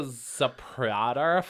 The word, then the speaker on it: Zapruder: Sort of like the Zapruder film. Uh-huh. One day we'll unlock its secrets. – I think that's actually how you pronounce that name. It's perfectly accurate.